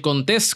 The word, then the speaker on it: Contés: Mi nombre es Miguel Contés con acento en la E y nos vemos la próxima.